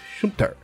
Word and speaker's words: Schumpeter: tais como Schumpeter, é assim que se pronuncia, Schumpeter,